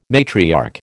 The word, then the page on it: matriarch: American English